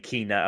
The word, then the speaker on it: Kena: I thought it was Kenna too until I played it and they started saying Kena. I was like, oh, long.